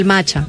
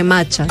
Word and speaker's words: matcha: O sea, perdón, el matcha. No se dice matcha, se dice matcha.